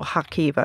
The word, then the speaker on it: Kharkiv: It goes through the human cost of Putin's war in Ukraine through the many victims that he's met in one of Kharkiv's hospitals, or Kharkiv, I should say, is the correct pronunciation.